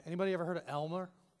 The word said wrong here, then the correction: Elma: Just said it wrong. Elma?